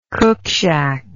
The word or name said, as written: cookshack